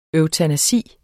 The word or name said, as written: eutanasi